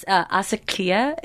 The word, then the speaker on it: Asakia: It's Asakia. It's Ugandan. – Uh-huh. Okay. – Pronounced like it has like that Swahili.